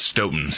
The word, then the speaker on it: Stoughton: Stoughton, is that how you say it? Or? – It's pronounced Stoughton.